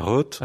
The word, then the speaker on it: Rote: Pourquoi un dossier nullité est transmis à la ROTE du Vatican? La ROTE, je ne sais pas, excusez-moi, je ne sais pas comment on prononce. – Alors on dit la ROTE.